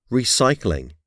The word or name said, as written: recycling